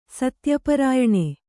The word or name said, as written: ಸತ್ಯಪರಾಯಣೆ